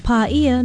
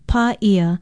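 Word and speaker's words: Pa'ia (Paʻia): Pa'ia is spelled P-A-Okina-I-A with a makron or kahako over the first vowel. It is best known as a small town in East Maui, and because it is so often misspelled, it is often mispronounced. Try it, Pa'ia.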